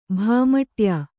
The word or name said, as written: ಭಾಮಟ್ಯ